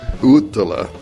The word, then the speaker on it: Uotila: J-U-K-K-I-S. and his last name is Uttila, U-O-T-I-L-A, Yukis Uttila.